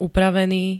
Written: upravený

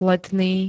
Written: letný

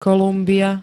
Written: Kolumbia

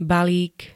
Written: BALÍK

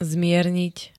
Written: zmierniť

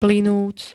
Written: plynúť

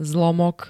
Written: zlomok

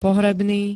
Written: pohrebný

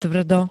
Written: tvrdo

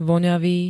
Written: voňavý

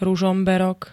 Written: ruzomberok

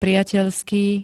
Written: priateľský

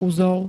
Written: uzol

